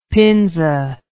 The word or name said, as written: pinza